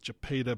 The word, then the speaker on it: Jephthah: And there was a man in the Old Testament, difficult name to pronounce. I believe the pronunciation is Jephthah,